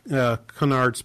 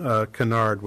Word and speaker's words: Conard: So the rest of Ed Conard's piece, and I have to be careful because it does sound like I'm saying Conard, which is sort of a false flag. His name is spelled with an O, C-O-N-A-R-D, is something that probably does require government action.